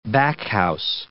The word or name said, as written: backhouse